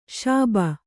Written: ಶುಭ

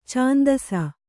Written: ಛಂದಸ್ಸು

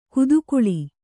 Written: ಕುದುಗುಳಿ

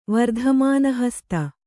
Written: ವರ್ಧಮಾನಹಸ್ತ

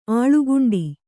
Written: ಆಳುಗುಂಡಿ

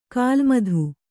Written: ಖಲಮತ

